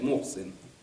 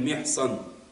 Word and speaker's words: Мехсан: Многие читают Мохсин. Уккяша ибн Мехсан. Правильно читается имя этого сподвижника.